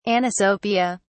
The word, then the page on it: anisopia: British English